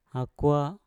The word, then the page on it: akwa: mpur